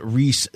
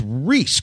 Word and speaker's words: Rhys: Now, I'm going to pronounce this as I would John Rhys Davies.